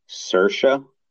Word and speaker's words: Saoirse: But Google is saying it is pronounced Saoirse. Yeah, the spelling is Saoirse. – Yes. – Ronan. Yeah, but it says Saoirse. – Well, if that is the case, I will say Saoirse and apologize for my hideous pronunciation earlier, because I honestly try to be phonetic with it. And I do know that with, like, Gaelic spelling, it's not what it would be phonetically.